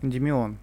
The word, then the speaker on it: Эндимион: Ты говоришь про Гиперион и переход э, к Эндименю. – Да. – Как Эн, Эндимион. – Эндимион. Эндимион, правильно, ударение, да.